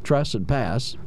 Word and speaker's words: trespass: Two syllables, tress and pass.